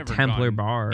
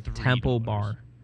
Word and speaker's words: Temple Bar: Temple Bar. – Yeah, it's not Templar. Like, we're not doing Crusades. We're not killing Muslims.